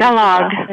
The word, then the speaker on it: Balogh: Is Balogh how you say it? Balogh.